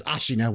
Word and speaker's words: Ashina: the initial tutorial area and then the Ashina outskirts that you first get to, which, you know, Kyle let us know that it is Ashina when he was on the show that one time.